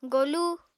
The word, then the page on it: golu: Apatani